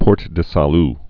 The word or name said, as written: Port du Salut